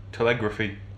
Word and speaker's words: telegraphy: An improvement in telegraphy. Telegraphy. Telegraphy? How was it pronounced? – Telegraphy.